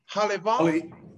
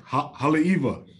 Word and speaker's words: Haleiwa: Haleiwa. – Haleiwa.